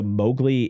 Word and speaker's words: Mowgli: So Mowgli in the book, and I guess how it's actually supposed to be pronounced, is Mowgli.